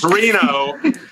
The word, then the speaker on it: Reno: And it's Reno. It's Reno, not Reno.